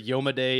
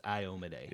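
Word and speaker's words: Iomedae: You know, I think there's a huge like following between different pronunciations of that name. Because I've heard Yomade, Iomade, yeah. – Amade. – The one I have heard from the creators, Paizo employees themselves, it's Iomade.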